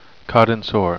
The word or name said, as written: cadinsor